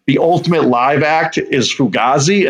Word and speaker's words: Fugazi: Oh, it's Fugazi is how you say it.